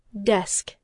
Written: desk

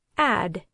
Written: add

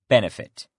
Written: benefit

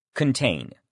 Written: contain

